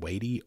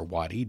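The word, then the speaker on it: Watie: They came under attack from Indian Home Guard, understand Wadey or Wadi, depending on who you are and how you want to pronounce his name, which was the last Confederate general to actually surrender.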